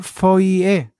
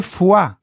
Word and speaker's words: foie: Vamos, el siguiente es de arroba ay Dice, no se dice foie, se dice fuá.